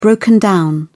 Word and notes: broken down: British English

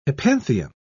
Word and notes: hypanthium: British English

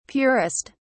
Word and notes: purest: British English

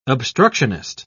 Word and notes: obstructionist: British English

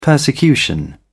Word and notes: persecution: British English

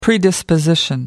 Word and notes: predisposition: American English

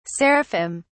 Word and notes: seraphim: British English

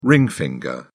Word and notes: ring finger: British English

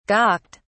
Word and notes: gawked: British English

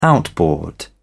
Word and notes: outboard motor: British English

